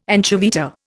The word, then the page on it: anchoveta: Indian